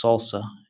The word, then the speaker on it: SLSA: for software artifacts, SLSA, or pronounced SALSA, and you can find that at slsa.dev, and we'll have a link in the show notes.